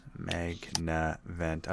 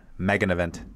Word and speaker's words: Magnavent: Magnavent. I'm always tempted to put an extra syllable in there. Magnavent.